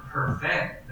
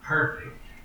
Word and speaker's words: perfect: Now, by that word perfect there, you may have looked at it and thought it was saying perfect. But there, even though it's spelled the same way, perfect carries with it a progressive connotation.